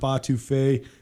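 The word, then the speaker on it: étouffée: Go try the shrimp etouffee and a variety of other...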